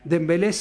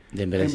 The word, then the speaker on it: Dembélé: Dembélé sí. – Dembélé sí. Mbappé sí. Due, porque hay una otra conformación con otra vocal. Pero vos las sentás con Mbappé o Dembélé.